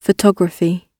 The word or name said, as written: photography